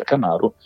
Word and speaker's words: KMRU: Chi invece ha recuperato molto della sua discografia è il nipote, perché c'è un Joseph Camaro tuttora attivo, che in arte è KMRU, quindi si pronuncia Camaro,